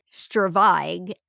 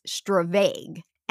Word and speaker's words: stravaig: I found it pronounced two different ways. One pronunciation is Stravag, and the other is Stravag. And I got this word. – Say these words again. – Stravag or Stravag.